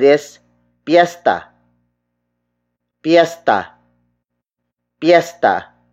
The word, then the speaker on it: piyesta: Piesta is spelled as P-I-Y-E-S-T-A. It means festival or fiesta. We also borrowed it from Spanish, but we spelled it according to how Tagalog speakers would pronounce it. May piesta means there is a festival. An alternate spelling of piesta is P-I-S-T-A or pista.